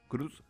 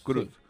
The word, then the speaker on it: Croods: ¿Croods? ¿Se dice así? – Los Croods. – Croods. – Sí.